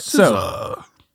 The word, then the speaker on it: SZA: SZA.